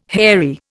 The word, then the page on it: hairy: Indian